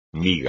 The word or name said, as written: miga